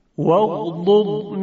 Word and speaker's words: واغضض: خب در این مثال ها یک حرف ضاد وجود داشت، اما در بعضی از کلمات دو حرف ضاد پهلوی هم قرار گرفته که تلفظ اونها مقداری مشکل تر هست. – واغضض من صوتك واغضض من صوتك واغضض من صوتك. – خب در اینجا دو حرف ضاد وجود داشت، یکی زاد مضموم وغض و دیگری ضاد ساکن وغض. خب طبیعتاً تلفظ این دو زاد یک مقداری نیاز به مهارت بیشتر داره که انشاءالله باید خوب تمرین کنید روی اینها.